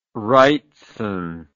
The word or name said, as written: wrightson